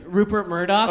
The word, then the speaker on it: Rupert Murdoch: Rupert Murdoch.